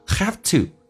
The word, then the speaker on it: have to: You have to，这边有一个弱化现象， have to 跟以前说的就是完全不一样的， V 不发音，发成 F 这个发音， to 说成 t， have to 就说成 have to，